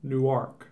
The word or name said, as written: Newark